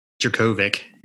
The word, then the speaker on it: Jurkovec: And the only reason why is because my boy, Phil Jurkovec, Jurkovec is his, really how you say his name. We've murdered his name on this podcast for so long.